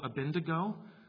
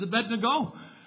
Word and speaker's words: Abednego: One of those pet peeves is calling Abednego Abendigo, which rhymes with indigo. If you call him Abendigo, I will correct it. All right? It is Abednego.